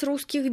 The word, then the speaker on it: русских: Сколько у вас русских денег? – What part of that means in Russian money? – Русских денег. – Did you hear the h sound at the end of the word for Russian?